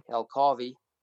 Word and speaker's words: El Carvi: The name of the bicycle is El Carvi, and it's basically a combination of our two names, and you say it like Ferrari, El Carvi.